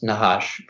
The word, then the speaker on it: Najash: it's spelled N-A-J-A-S-H. And I think that it should be pronounced Nahash because it comes from the Spanish form of the Hebrew word Nahash, which is what the snake in the Bible was called.